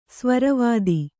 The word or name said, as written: ಸ್ವರವಾದಿ